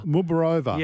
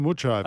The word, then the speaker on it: Muchova: At one stage, yeah, they were pronouncing her name. Was it Muborova. Yeah. – So now it's back to Muchova, okay. which is how it's spelt.